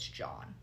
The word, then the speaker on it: Jean: So John, it's spelled like Jean, but it's John.